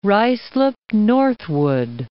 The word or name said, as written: Ruislip Northwood